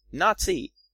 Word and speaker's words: Nazi: Well, Nazi are actually the two syllables of the first name of the party, National, Nazi, as it's pronounced in German, National.